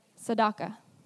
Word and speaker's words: tzedakah: T-S-E, D-E-Q-A-H. Tzedakah. Yeah, exactly how it sounds.